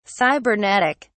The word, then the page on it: cybernetic: British English